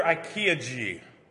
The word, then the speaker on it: ikigai: According to Brightspace blog, find your IKEA G.